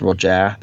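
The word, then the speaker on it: Roget: It's R O G E T. I'm fairly certain it's pronounced Roger.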